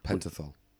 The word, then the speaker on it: Pentothal: Two psychiatrists gave Hirons sodium pentothal. – Pentothal. – Is that how you pronounce it? – Mm-hmm.